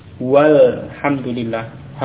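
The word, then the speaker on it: Walhamdulillah (والحمد لله): Tapi kalau ada wawu misalnya yang mendahului, bagaimana? Walhamdulillah. Hamzahnya pada alif lam tidak diucapkan ya. Wawu langsung ke lam.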